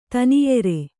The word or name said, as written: ತನೆಯಿರು